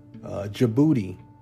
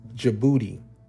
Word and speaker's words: Djibouti: Djibouti. It's spelled D as in David, J as in Jack, I, B as in boy, O-U, T as in Tom, I. It's pronounced Djibouti.